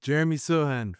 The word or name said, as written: Jeremy Sochan